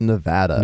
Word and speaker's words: Nevada: Which I recently learned apparently is pronounced Nevada. – Nevada. I was just about to say Nevada.